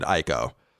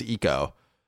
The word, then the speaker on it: Ico: So for years, I called it Ico. It wasn't until I started working at IGN that I was corrected that it's pronounced Eco.